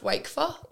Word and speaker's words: Wakfer: Do you have people pronouncing it Wafka? – Yeah, oh, 100%. It's actually pronounced Wakefa.